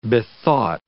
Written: bethought

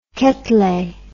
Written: Quetelet

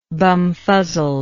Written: bumfuzzled

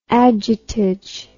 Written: adjutage

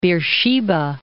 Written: Beersheba

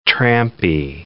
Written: trampy

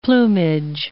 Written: plumage

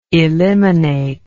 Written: eliminatory

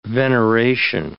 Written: veneration

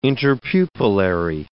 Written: interpupillary

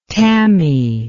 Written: tammy

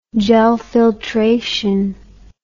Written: gelfiltration

